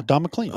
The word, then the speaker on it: Don McLean: No, Don McLean.